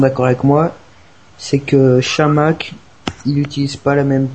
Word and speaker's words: Chamakh: l'attaque Chamac pour pour ceux qui le prononcent à la française. Donc voilà, Marwan Chamakh et Johan Gouffran en attaque, Exit Kavenaghi.